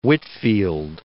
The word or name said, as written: Whitefield